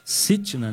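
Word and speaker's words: Sitnah: E o nome desse segundo poço, pastor Mário, eu não sei se é assim que pronuncia, mas a Bíblia fala Sitna, né? – Isso, que é S-T-N-A, né?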